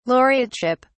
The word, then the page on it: laureateship: British English